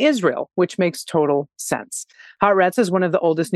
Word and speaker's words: Haaretz: the correct pronunciation of haaretz is and i said it this time haaretz um, pause between for those of you that are also learning this word, which is the newspaper in Israel pause between the first syllable and the second, no pause between the second and the third. So it's ha arets.